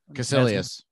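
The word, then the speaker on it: Kaecilius: Caecilius.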